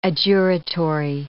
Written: adjuratory

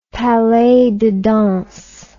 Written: palais de danse

